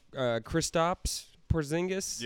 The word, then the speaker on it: Kristaps Porzingis: Kristaps Porzingis.